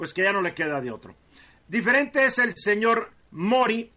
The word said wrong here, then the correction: Modi: que es el, el gobernante de la India, creo que pronuncié bien su nombre, Modi, Modi, el señor Modi, que sí lo ha usado desde el principio.